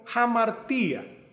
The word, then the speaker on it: hamartía: En la Biblia, especialmente en el Nuevo Testamento, cuando se refiere al pecado, se usa la palabra griega que se pronuncia como hamartía, que significa literalmente pecar.